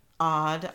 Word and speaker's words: Aud: And she's like, Odd. That's her name actually, is Odd. Is A-U-D, but it's mm-hmm. pronounced odd.